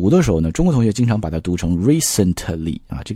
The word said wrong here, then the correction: recently: 这个 t 拉的太多了。我们完全可以做一个单词内的失去爆破，所以读的时候呢，你可以读成 recently。